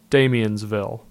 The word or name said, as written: Damiansville